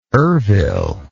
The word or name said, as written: ervil